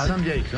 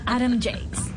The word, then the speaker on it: Adam Yates: ¿Cómo se dice? Adam Yates. Adam Yates, no. Sí, pero me ah. tiraba el chiste. ¿Quieres que lo diga así? – Sí. – Adam Yates.